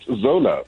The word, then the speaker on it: Xhosa: When I get to the UK, if I'm in London, then it's Xhosa, automatically. And I'm not going to correct anybody because that is the anglicized pronunciation of the X.